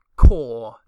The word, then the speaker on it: corps: I need to start saying I need to start pronouncing corpse properly. C O R P S core, charm core, charm core, like a charm corporation, charm core.